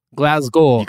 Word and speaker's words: Glasgow: The shitty Willy Wonka experience that was put on in Glasgow. How'd I do? – Glasgow. You got to fight how you want to phonetically say it when you see G-O-W.